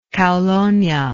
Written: caulonia

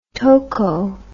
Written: toco

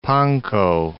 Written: Pankow

